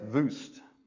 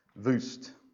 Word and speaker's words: Wuest: Kenneth Vuest, that's spelled W-U-E-S-T, Vuest, was a 20th century Greek scholar.